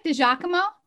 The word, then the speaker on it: Di Giacomo: DiGiacomo? Well, you made me French and I'm actually Italian. so it's, it's Di Giacomo, but close.